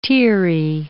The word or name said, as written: teary